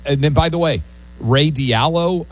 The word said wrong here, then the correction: Dalio: I may be mispronouncing Dalio. – His name, Dalio, $75 million, $250 each for Connecticut kids.